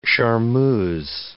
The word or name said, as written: charmeuse